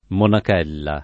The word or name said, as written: monachella